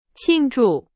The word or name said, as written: 庆祝